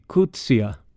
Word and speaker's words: Coetzee: Och tydligen ska den första stavelsen uttalas ungefär som i engelskans book, medan den sista stavelsen ska uttalas som sista stavelsen i idea. Alltså bör det bli Coetzee eller liknande.